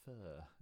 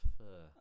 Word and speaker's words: pho: is it a fur? Is that how you say it? P-H-O.